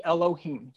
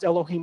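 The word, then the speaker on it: Elohim (אלהים): And we pronounce it the way that we would pronounce it would be Elohim. You may have heard that term before. It's a Hebrew word pronounced Elohim when you see the word God.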